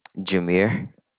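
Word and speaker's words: Jameire: Jameer. – How do you spell that? – J-A-M. – Uh-huh. – E-I-R-E.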